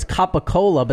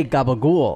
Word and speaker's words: capicola: It's capicola, but they say gabagool.